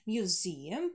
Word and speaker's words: museum: Первое – это музей, museum. Присаживаемся на второй долгий – museum, museum, museum.